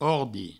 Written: ordi

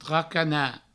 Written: tracanat

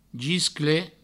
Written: gisclet

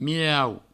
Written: mirau

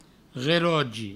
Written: relogi

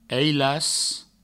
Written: ailas

